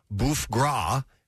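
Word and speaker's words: bœuf gras: Are during the 16th century, the ancestors celebrated bouffe gras. Is that how you pronounce that, Nick? – Yeah.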